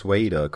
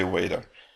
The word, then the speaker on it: Vader: Well, it had been said that it's very hard for us Austrians to pronounce Vader correctly, so. – Yeah, I heard that. Yeah, it's funny to hear us say waiter.